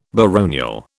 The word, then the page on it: baronial: British English